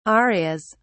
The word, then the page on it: arias: British English